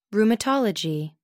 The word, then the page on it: rheumatology: American English